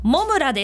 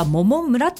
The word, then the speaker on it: 百村: J6 さん、あ、我が町稲城、北稲城、好きな難読地名、稲城市のモムラです。モムラ、どうやって皆さん書くか想像つきます？モムラ、稲城市のモムラ、百の村と書いてモムラなんですよ。百村とかモモ村って読みたくなるところ、モムラなんですね。